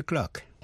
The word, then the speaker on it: clerk: Thế còn cách phát âm của chữ clerk, miền Nam nước Anh, trong tiếng Anh chuẩn, received pronunciation, chữ clerk đọc là clerk. Trong tiếng Mỹ, đọc là clerk, với vần ơ. Tiếng Anh đọc là clerk, với vần a. Bây giờ ở Mỹ, chỉ dùng chữ clerk, C-L-A-R-K, để chỉ tên người mà thôi.